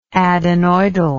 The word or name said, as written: adenoidally